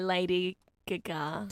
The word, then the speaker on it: Gaga: That is Just Dance by Lady Gaga. Just dance, gonna be okay. And congratulations on the correct pronunciation of Gaga. That's how it should be said.